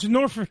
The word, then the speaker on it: Norfolk: It's Norfolk. We're saying it wrong. It's Norfolk.